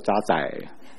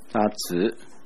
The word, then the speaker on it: 渣滓: 这里有几个字注意一下吧。这个二十二节你的银子变为渣子，那个字读子，不要读作渣仔，渣子，来讲一遍，渣子。